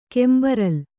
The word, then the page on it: ಕೆಂಬರಲ್: kembaral